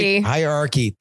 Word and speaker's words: hierarchy: Jen, basing your entire hierography? How do you say that? – Hagiography? – Hierarchy. There we go.